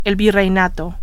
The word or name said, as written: virreinato